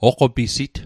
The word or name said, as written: oqopisit